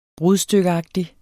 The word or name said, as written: BRUDSTYKKEAGTIG